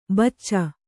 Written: ಬೂಚಿ

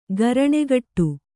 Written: ಗರಣೆಗಟ್ಟು

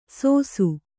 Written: ಸೋಸು

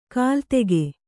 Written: ಕಲಾದಕ

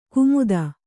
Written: ಕುಮುದ

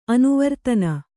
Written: ಅನಾವರ್ತನೆ

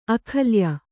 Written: ಅಕಲಾಯ

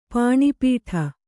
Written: ಪಾಣಿಪೀಠ

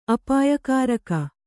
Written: ಅಪಾಯಕಾರಕ

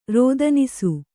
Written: ರೋದನಿಸು

